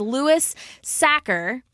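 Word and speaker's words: Sachar: and this one I definitely know how to pronounce his name, and it is Lewis Sacker.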